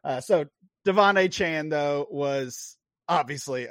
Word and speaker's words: Achane: I did see a note from Schefter, from Adam Schefter, earlier today that after the game, that the running back noted that his name should be pronounced Devon Achane. – Okay. – Devon Achane. So that's going to take some getting used to. Not Achane, but Achane. So he's earned it.